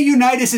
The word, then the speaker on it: Unitas: Johnny Unitas is actually how you say his name, and it's always been that way.